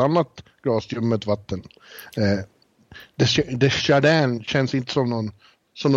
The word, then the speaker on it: Desjardins: Ja, Desjardins. Desjardins kan man säga. – Alltså, om franska eller amerikansk uttal.